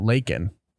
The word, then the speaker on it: Lakin: Uh, one you did miss was talking about Lakin rhymes with quaking.